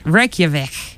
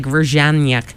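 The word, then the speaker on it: Reykjavík: Reykjavik. I always want to call it like Reykjanyak.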